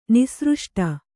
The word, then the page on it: ನಿಸೃಷ್ಟಿ: nisřṣṭi